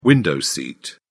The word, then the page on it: window seat: British English